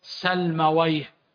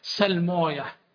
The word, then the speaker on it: سلمويه: ان اللغويين يقولون سيبويه نفطويه سلمويه، لكن المحدثين يقولون سلموية نفطوية هكذا.